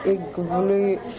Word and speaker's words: Ivalu: They pronounced it Ig as in fig, Vil as in will, and Lu as in you. Igvlilu.